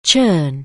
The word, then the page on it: churn: anglais